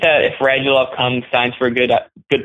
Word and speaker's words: Radulov: I think that while he's good, especially if we get somebody like, is it Radulov? Is that how you say his name? – Yeah.